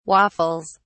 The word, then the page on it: waffles: British English